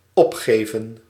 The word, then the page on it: opgeven: /ˈɔpˌxeːvə(n)/